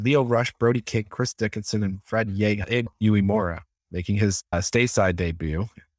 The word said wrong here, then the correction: Uemura: Butchered his name, Yuya Uemura.